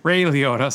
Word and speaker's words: Liotta: Did I say it wrong? – Yes. Try it again. – What's his name? – You said Lay Riota. – Oh, oops. – Ray Liotta, sorry.